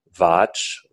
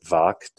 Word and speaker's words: vach: The word for ritual speech from the Rig Veda forward is vach, sometimes pronounced vak, depending on the context.